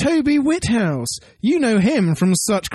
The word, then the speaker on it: Whithouse: Did you say his name is Whithouse, as in the House of Wit? – Yes. – Wit. – With an H.